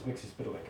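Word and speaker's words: Mxyzptlk: Which is Mixius Piddly.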